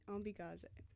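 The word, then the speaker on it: Ombiigizi: So I think Ambigize. Ambigaze? I think we were pronouncing it wrong. Ambigaze. And it means this is noisy.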